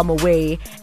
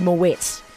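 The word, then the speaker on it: Moët: Someone went and said people don't know how to spell Moway, and then they corrected that person and said it's actually Moet.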